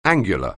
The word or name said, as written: angular